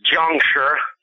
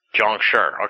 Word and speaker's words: Jiangshi: So tell me about what this Jianshi is and what you've uncovered about this this creature, I guess. – Okay. Well, the correct pronunciation is Jiangshi. – Jiangshi.